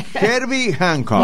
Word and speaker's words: Hancock: Se está riendo Manu de Herbie París. – Hancock. Está bien, no lo dije bien. Herbie Hancock.